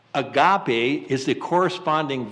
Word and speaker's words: agape: The actual Greek word, whether it's love or charity, in whatever translation you have, is spelled in English, A-G-A-P-E, agape, agape love.